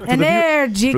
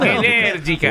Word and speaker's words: Energica: Energica. – Look, Energica. – For the viewers at home, it's Italian. You got to talk with your hands, so they can't really appreciate it. – Energica.